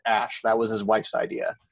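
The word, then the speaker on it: Æ: That's pronounced Ash.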